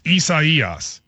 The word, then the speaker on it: Isaias: People are saying, Matt, you place the syllable on the wrong, the emphasis on the wrong syllable. It goes on the third syllable. So it's Isaias.